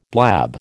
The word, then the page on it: blab: American English